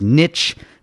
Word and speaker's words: niche: It's pronounced niche, not niche.